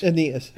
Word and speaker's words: Aeneas: He, excuse me, how do you say that name? Is it Aeneas? – It's Aeneas.